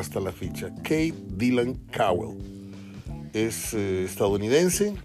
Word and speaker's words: Cowell: Esta contratación de un muchacho de 20 años, de nombre Kate Caldwell, otra vez Kate Cowell, lo dije mal al principio, no lleva D anterior a, la W, es Kate Cowell o Cowell.